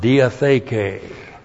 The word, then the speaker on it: diatheke: And kainos modifies the noun diatheke. D-I-A-T-H long E K long E. Diatheke.